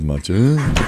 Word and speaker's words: matcha: I said mocha, of course. And the guy was like, it's called matcha.